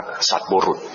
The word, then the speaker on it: สัตบุรุษ: สัตบุรุษสัตบุรุษอ่านว่าสัตบุรุษนะไม่ใช่สัตตบุรุษนะสัตบุรุษ